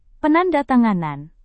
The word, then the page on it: penandatanganan: Indonesian